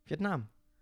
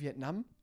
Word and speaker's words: Vietnam: Ja, wie hast, du das, wie hast du das Land gerade ausgesprochen? Vietnam. Heißt es nicht Vietnam? Mit einem kurzen A? – Ja, aber es ist ja mein Land.